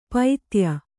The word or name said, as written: ಪೈತ್ಯ